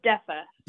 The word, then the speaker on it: Defa: How do you say it? – It's Defa.